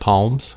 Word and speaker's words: palms: You mean like palms? – Yeah, it's not. You don't have to say what the L. Palms. – Palms? – The L is silent.